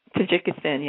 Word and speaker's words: Tajikistan: So have you ever been to, am I saying it right, Tajikistan? – Tajikistan, yes. Yeah, I went to Tajikistan.